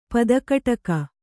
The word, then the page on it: ಪದಕಟಕ: Kannada